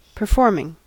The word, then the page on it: performing: American English